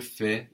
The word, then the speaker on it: fais: So we have je fais, no, F-A-I-S, and you do is tu fais, spelled the same, F-A-I-S.